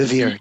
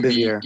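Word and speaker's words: Bevere: And I remember someone gave me a book called The Bait of Satan by John Bivery. I think that's how you pronounce his name. – It's Bivier. John Bivier. – B-E-R-E.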